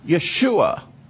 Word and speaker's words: Yeshua: And in Greek, that transliteration is spelled Yeshua. That's how you would say that.